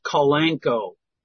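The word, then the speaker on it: Kalanchoe: It's pronounced Calanqueo.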